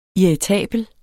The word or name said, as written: irritabel